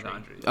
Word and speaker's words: Gondry: How do you pronounce his name? – Michel, Gondry. Gondry.